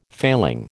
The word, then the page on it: failing: American English